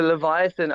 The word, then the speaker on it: Leviathan: Okay, talk to us about the Leviathan. Am I saying it correctly? – Yes, you are pronouncing that correctly.